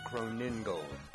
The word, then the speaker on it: Krohnengold: You are exactly pronouncing it wrong. It's Krohnengold.